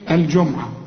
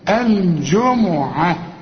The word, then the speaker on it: الجمعة: من يوم الجمعة، خد بالك الميم هنا في النص عليها الضم، عشان في ناس كتير يقول لك ايه الجمعة الجمعة، لا الجمعة، واخد بالك؟